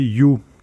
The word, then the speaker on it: Yuh: Ce rapport coïnciderait presque avec le lancement fait avec tombour et trompette de la banque code pour son nouveau bébé, j'ai nommé You, qui s'écrit Y-U-H une banque en ligne franchement orientée jeune public tant elle est simple d'utilisation.